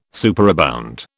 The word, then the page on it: superabound: British English